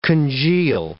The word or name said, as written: congeal